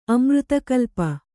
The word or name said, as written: ಅಮೃತಕಲ್ಪ